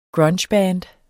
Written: grungeband

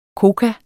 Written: koka

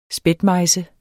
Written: spætmejse

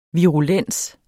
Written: virulent

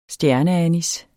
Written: stjerneanis

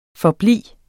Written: forblive

